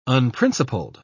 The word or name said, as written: unprincipled